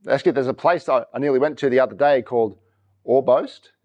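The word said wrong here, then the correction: Orbost: Or Bost.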